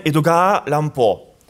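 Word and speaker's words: Edogawa Rampo: Si on prononce Edgar Allan Poe en japonais, d'abord c'est comme ça, Edoga Allan Et quand on le prononce, c'est Edoga Allan Si on mix, en tout cas si on découpe autrement, ça devient Edogawa Lampo.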